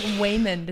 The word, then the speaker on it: Waymond: But it sounds like Waymond is how you would say his name.